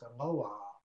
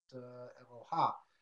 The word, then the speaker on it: Eloah: the word Aleph Lamed Vav Hey, as pronounced Eloah, not Eloha.